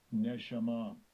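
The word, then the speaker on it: Neshama (נשמה): Strong's H, 5397, Neshama.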